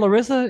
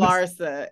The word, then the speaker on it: Larsa: Not Larissa? Is Larsa? – It's Larsa. – Well, I've been saying it wrong. – L-A-R-S-A.